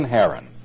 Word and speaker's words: Haran: They named it after his brother who had died, Haran, H-A-R-A-N. In the New Testament, it's spelled with a C-H because it in Hebrew would be pronounced like Haran, something like that. My Hebrew is not the best, but it's almost like you're hocking up a loogie or something, you know.